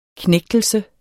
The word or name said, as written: knægtelse